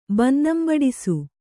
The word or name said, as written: ಬನ್ನಂಬಡಿಸು